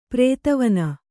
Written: ಪ್ರಧ್ವಾನ

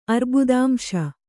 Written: ಅರ್ಬುದಾಂಶ

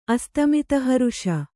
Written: ಅಸ್ತಮಿತಹರುಷ

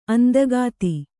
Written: ಅಂದಗಾತಿ

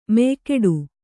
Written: ಮೆಯ್ಗಿಡು

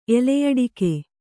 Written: ಎಲೆಯಡಕೆ

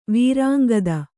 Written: ವೀರಾಂಗದ

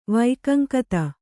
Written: ವೈಕಂಕತ

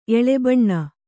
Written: ಎಳೆಬಣ್ಣ

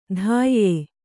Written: ಧಾಯ್ಯೆ

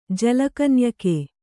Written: ಜಲಕನ್ಯಕೆ

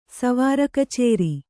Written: ಸ್ವಾರಕಚೇರಿ

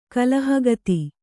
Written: ಕಲಹಗತಿ

